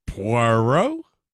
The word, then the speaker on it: Poirot: Poirot? – Thank you.